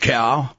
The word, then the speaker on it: chao: "Sacred cow?" – Simon asked. – "It's pronounced that way, but you spell it C-H-A-O.